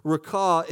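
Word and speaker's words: Raca: It should be pointed out to say that rakah is actually the way that it's pronounced, rakah, is actually as if you are spitting on someone else.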